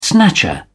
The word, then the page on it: snatcher: British English